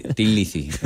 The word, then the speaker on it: Thin Lizzy: Y para este disco, como yo llevaba 5 o 6 años yendo con él de copiloto, escuchando Thin Lizzy, creo que se dice así, ¿no? – No, vale. – Mucho mejor que el consejero. – Discos de... – Thin Lizzy. – Eso, Thin Lizzy.